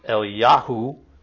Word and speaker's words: Eliyahu: And Elijah, if you pronounced it in Hebrew, would be Eliyahu.